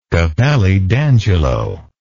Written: capelli d'angelo

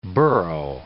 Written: burgh

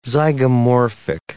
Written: zygomorphic

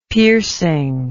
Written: piercing

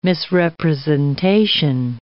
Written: misrepresentation

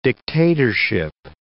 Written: dictatorship

